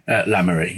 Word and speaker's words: Le Marais: Is it Le Marais? – La Marais, yeah.